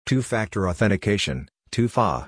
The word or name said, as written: Two-Factor Authentication (2FA)